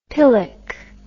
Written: pillock

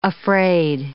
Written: afraid

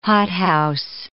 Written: hothouse

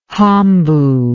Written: hambo

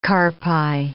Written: carpi

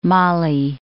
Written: mali

